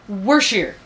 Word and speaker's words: Worcestershire: Okay, just so that we're clear, what was the correct pronunciation? – Worcestershire.